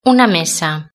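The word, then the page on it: una mesa: Spanish (Spain)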